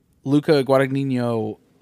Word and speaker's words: Guadagnino: That underneath the tennis court shot, Luca Guadagnino wanted to do that for... That's how you say his fucking name.